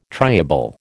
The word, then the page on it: triable: American English